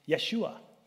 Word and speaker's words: Yeshua: Joshua, if you were to pronounce it in Hebrew, it'd be Yeshua. Yeshua in Greek is, guess what?